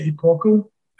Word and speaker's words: Epochal: It's I would say Epochal. – Epochal. – But that's the first time I've heard Epochal. But I think that's the American English pronunciation of the word.